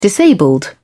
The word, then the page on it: disabled: British English